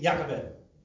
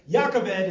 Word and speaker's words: Yocheved: It's a story about a woman named Jochebed. That's with a J. she has the hand spell that I feel so bad. Yocheved is how you say it in Hebrew.